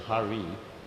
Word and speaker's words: Hare: If you say Hare, that's Krishna. You say Hare. And you say Hara, it's someone different. So you got to be careful how you pronounce it.